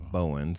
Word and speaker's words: Bowen: I had a boss who called me Mo Bo because my last name is Bowen. So, and it rhymes with favorite person.